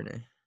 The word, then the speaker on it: Duvernay: I don't know if that's how you pronounce his last name. – Yeah, Duvernay.